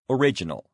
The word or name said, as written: original